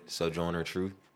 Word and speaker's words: Sojourner Truth: Sojourner Truth.